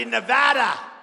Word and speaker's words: Nevada: I have a beautiful building right down the road with the proud, hardworking patriots of Nevada. You got to say Nevada. You say that wrong, you got problems. You say Nevada, they go, what the hell? Gotta be Nevada.